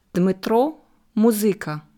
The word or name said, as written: Dmytro Muzyka